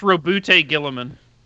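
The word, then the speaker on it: Roboute Guilliman: I think it's Rabute Guilliman. – Okay. Rabute Guilliman. – It's default to how they say it on the audiobooks.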